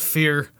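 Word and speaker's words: Fier: It's F-I-E-R. – It doesn't matter. It's pronounced Fear.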